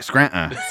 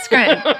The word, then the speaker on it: Scranton: And if you're from here, you don't pronounce the T. – Right, Scranton. – Scranton. – Scranton.